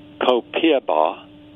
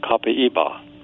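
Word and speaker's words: Copaíba: And while many Americans are pronouncing Copiaba, the Brazilian pronunciation is Copaiba.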